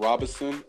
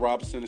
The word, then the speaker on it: Robinson: Once again, I'm Nissan Robinson, the real estate expert. I'm on YouTube with Nissan Robinson. It's spelled N-E-S-A-H-N Robinson, R-O-B-I-N-S-O-N, Nissan Robinson.